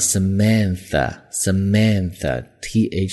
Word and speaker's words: Samantha: 那注意这个女生的名字读作 Samantha，TH 是有一个咬舌头的轻辅音在里面。